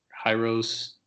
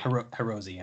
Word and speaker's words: Hirose: Going down from there, Tyler Bertuzzi, Taro Hyros, not sure actually how to pronounce that. – You had it right the first time. – Rosie? Great. – Hirose, yeah.